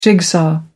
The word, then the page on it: jigsaw: American English